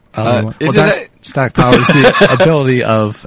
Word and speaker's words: diastatic: Diastatic. – Thank you. – It was written as diastic, by the way. – Well, because, you know, she doesn't know how to pronounce it.